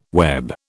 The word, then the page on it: web: British English